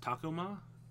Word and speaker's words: Tacoma: I believe it's pronounced Tacoma. – Tacoma. – Tacoma. – You call it a Toyota Tacoma, not a Toyota Tacoma.